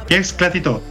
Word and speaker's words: Que Esclati Tot: It is Siderland and Que Esclati Tot. Is that the way to say it? – That's right, JP. Well, I don't speak Catalan myself, but that's how you pronounce it. Que Esclati Tot.